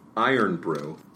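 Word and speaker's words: Irn Bru: This item is called Iron Brew. It's spelled Iron Brew. – I-R-N space B-R-U.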